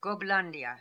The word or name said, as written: Goblandia